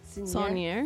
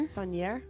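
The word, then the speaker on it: Saunière: How do you say it? – Saunier. – Saunier. – Is how I say it. – Yeah, I'm pretty sure that's how they said it.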